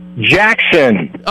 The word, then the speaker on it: Jackson: Jackson.